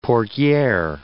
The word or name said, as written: portiere